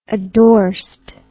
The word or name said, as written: addorsed